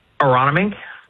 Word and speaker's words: Aronimink: Aronimink. – Cool. – Yeah, that's what I said. – Yeah, I heard Aronimink yesterday.